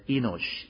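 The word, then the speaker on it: enosh (אנוש): And the first word, what is man, that word is coming from a Hebrew word pronounced as enosh, E-N-O-S-H, enosh.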